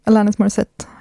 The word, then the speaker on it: Alanis Morissette: Det där var då alltså You Oughta Know med Alanis Morissette, om jag uttalar det rätt. – Ja, Alanis Morissette.